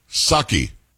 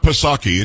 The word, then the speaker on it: Psaki: Psaki. It's not Psaki. It was just Psaki. You didn't pronounce the P.